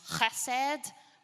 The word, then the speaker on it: chesed: But it is the Hebrew word hesed. Now, if we were really going after language, it's actually pronounced chesed, right?